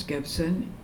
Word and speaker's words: Gibson: My name's Eunice Gibson, E-U-N-I-C-E, Gibson, G-I-B-S-O-N.